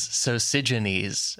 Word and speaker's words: Sosigenes: I wish I have been struggling with the pronunciation of this guy's name, and Leapington would be easy. His name is Sosigenes, as far as I can find.